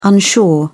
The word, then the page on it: unsure: British English